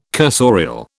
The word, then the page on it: cursorial: British English